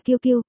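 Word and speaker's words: QQ: Còn gọi là Tencent Quy Quy Thu Thu, phát âm là Kiêu Kiêu, gần giống Quy.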